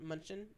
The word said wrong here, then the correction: Mnuchin: Mnuchin? – There we go.